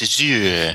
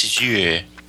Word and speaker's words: Azure: Say it like that and it sounds like a sewer. – Yeah, it's Azure.